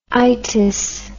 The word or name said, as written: itis